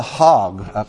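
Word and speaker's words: chag: While they're switching over the presentations, real quickly here, when you look at the festivals in Leviticus 23 and elsewhere, they're called hog. Hog is actually the better way of pronouncing it.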